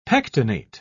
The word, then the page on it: pectinate: British English